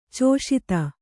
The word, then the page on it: ಚೌಶೀತಿ: cauśiti